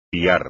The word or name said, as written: piar